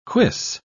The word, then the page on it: cuisse: British English